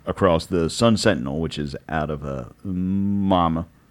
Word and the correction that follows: Miami: Is that how you say it? Mama. Oh, Miami. – Miami. – That's how Cuomo says Miami.